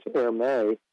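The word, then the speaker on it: Hermès: And there's a designer, I guess you pronounce it Hermes. Um, I'd call it Hermes, but uh, I think in the French it's Hermes.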